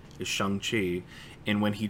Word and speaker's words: Shang-Chi: And he says Shang-Chi, which is how you would pronounce it in Chinese.